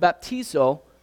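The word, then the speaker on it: baptizo (βαπτίζω): And when you look at the Greek word for baptize, it's pronounced baptizo.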